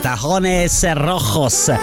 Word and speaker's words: Tacones Rojos: è il disco di Sebastian Yatra con John Legend, si chiama Tacones Rojos, detto bene, Tacones Rojos.